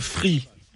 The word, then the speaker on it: Phree: Alors d'abord, ce stylet s'appelle Free. – D'accord. – Mais ça s'écrit PH.